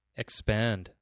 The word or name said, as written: expand